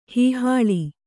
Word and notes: ಹೀಹಾಳಿ: hīhāḷi; noun

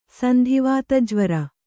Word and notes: ಸಂಧಿವಾತಜ್ವರ: sandhi vāta jvara

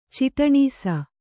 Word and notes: ಚಿಟನೀಸ: ciṭanīsa

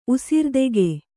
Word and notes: ಉಸಿರ್ದೆಗೆ: Kannada

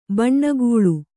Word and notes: ಬೆಣ್ಣೆಗಳೆ: beṇṇegaḷe

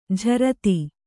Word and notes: ಝರತಿ: jharati

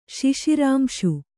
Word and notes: ಶಿಶಿರಾಂಶು: kannada

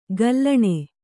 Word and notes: ಘಲ್ಲಣಿ: ghallaṇi

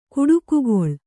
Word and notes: ಕುಟುಕುಗೊಳ್: kuṭukugoḷ

